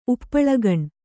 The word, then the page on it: ಉಪ್ಪುಳಗಣ್: Kannada